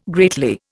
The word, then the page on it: greatly: Indian